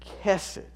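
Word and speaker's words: chesed (חסד): The principal Old Testament Hebrew word that is used to describe how God relates to us is chesed, spelled H-E-S-E-D, pronounced with kind of a hard K at the beginning, chesed.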